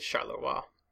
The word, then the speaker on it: Charleroi: Last week, we discussed General Lanrezac and his French Fifth Army as they dealt with the German attack at Charleroi. I would like to thank listener Stephen Mitchell for pointing out that I have been horrifically mispronouncing Charleroi in previous episodes.